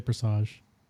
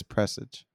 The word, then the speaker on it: Presage: Also got my final pattern of Beloved on the is it Prestige or Presage? How do you say that? – I would say Presage. – Okay, that's how I would say it too. – No. Osiris says it in game. He says Presage.